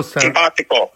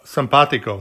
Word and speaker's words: Simpático: Mills about his new book, Billy the Kid, El Bendito Simpático. How do I say that? El Bendito Simpático. – Simpático. – Simpático. – There you go.